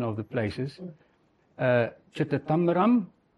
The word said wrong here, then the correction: Chidambaram: Chidambaram. – Chidambaram.